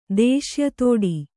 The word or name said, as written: ದೇಶ್ಯತೋಡಿ